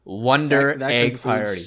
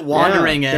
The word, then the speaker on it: Wonder Egg Priority: So don't worry, listeners, we will be talking about Wonder Egg Priority. There we go. My entire team has been giving me crap because I've been saying it correctly. – He calls it Wandering Egg.